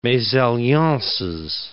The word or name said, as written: mesalliances